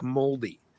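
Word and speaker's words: Māori: I have family that lives in New Zealand, and every time they pronounced Maori to me, it sounded like moldy. – Yeah, and that's like, how they say it.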